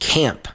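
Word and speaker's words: Caamp: Up next, let's turn our attention to the Midland, which on September 12th will host the band Camp. Camp is spelled C-A-A-M-P. I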